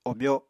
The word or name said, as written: obyo